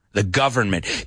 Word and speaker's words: government: Careful with the pronunciation here. La V hay que pronunciarlo. Dilo conmigo. The government. The government. La N es un poco muda, ¿eh? Porque es government. Pero la N se come, se una con la M, government.